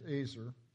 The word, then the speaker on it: ezer: In your notes, I've included just a little clip about the helper, the Ezer. Actually, the right pronunciation is Azer, but I've always, not Jewish, so I mispronounce it.